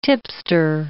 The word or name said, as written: tipster